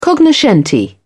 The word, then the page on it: cognoscenti: British English